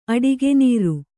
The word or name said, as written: ಅಡಿಗೆನೀರು